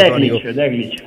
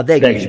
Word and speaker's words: The Click: Che se non sbaglio è colui che si definisce The Click o The Click, non so l'accento. – The Click. – Ah, The Click, perfetto, che sì.